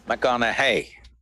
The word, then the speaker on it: McConaughey: McConaughey. Rhymes with, what would Madonna say?